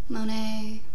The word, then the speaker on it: Monet: Artists featured in the museum include Rembrandt, Michelangelo, Raphael, Monet, is that how you say it right? – Yeah. – Degas? I think D-E-G-A-S.